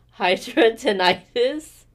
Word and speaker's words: Hidradenitis: Hidradenitis, right? – Mm-hmm.